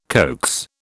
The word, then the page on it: coax: British English